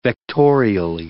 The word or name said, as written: vectorially